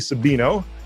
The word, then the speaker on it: Sabino: This is Nancy Sabino, and I'm pretty sure I'm pronouncing your last name right, am I? – Yes, you are. – Yes! – It is. It's Sabino with a B, and I confuse people all the time.